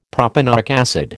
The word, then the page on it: propanoic acid: American English